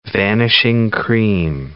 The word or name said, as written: vanishing cream